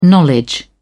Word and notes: knowledge: British English

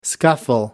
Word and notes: scuffle: American English

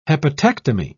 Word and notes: hepatectomy: British English

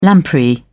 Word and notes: lamprey: British English

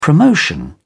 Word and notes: promotion: British English

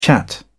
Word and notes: chat: British English

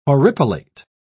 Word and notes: horripilate: British English

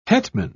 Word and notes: hetman: British English

